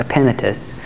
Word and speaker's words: Epaphras: And this guy Epaphras, that's how you pronounce it, Epaphras, was the first believer in that region.